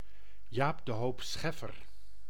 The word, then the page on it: Jaap de Hoop Scheffer: [ˈjaːb də ɦoːp ˈsxɛfər]; Dutch